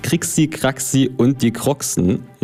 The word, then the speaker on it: Krixi Kraxi und die Kroxen: Er hat auch zahlreiche Nebenprojekte wie die Band Krixi Kraxi und die Kroxen, ja, so wird es tatsächlich ausgesprochen, und seine Musik allgemein zeichnet sich durch den Wiener Schmäh und die Bezüge zur Stadt Wien aus.